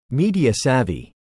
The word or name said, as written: media-savvy